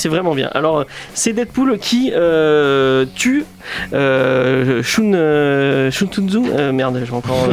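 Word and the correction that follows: Sun Tzu: Oui, Sun. Tzu,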